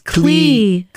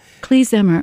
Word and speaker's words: klezmer: Is it Klezmer or? – It's Klezmer. – Klezmer, okay.